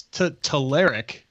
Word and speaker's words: Telerik: First, I want to say we've been pronouncing it wrong for years, apparently. At least we have, Jason. – Yep. – So it's Telerik.